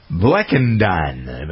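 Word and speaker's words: Blechynden: Anyway, the primary impetus for the expanded popularity of tea credited to Richard Bleckenden. That's how you pronounce his name. Man, spell this B-L-E-C-H-Y-D-E-N.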